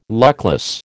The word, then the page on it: luckless: American English